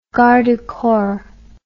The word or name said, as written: garde du corps